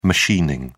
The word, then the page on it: machining: British English